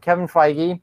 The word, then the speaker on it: Feige: Kevin Feige, Brian, I said it right. Kevin Feige has, thank you.